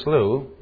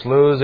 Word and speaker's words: slough: So for our purposes, we're going to pronounce it slough, which is the authorized and correct way.